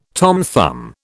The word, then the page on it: Tom Thumb: British English